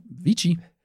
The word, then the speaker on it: Vichy: Not Veni or Vidi? – Nope, Vichy. 1946, Vichy, I suppose is how a French person would pronounce it.